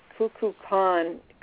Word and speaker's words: Kukulkan: So anyway, the Mayan elders, Kukulkan, that's how it's pronounced.